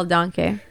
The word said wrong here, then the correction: Denke: I just realized that it's not pronounced Donkey, as fun as that sounds. It's actually Carl Denke.